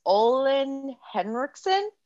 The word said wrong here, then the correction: Ole Henriksen: It's Ole Henriksen.